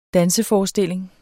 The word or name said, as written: danseforestilling